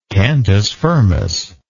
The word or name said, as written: cantus firmus